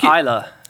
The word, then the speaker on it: Islay: I think it's Islay. – Is it Islay? – Yeah. You said it right the first time.